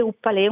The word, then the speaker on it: Upalè: Sì, esatto, Upalè, che alla fine vuol dire, ho messo insieme up, che in inglese vuol dire telaio, e Ale è la parte iniziale del mio nome. Le ho fuse insieme, mi suonava bene con l'accento finale, Upalè, una sorta anche di incoraggiamento ad andare avanti con questo progetto.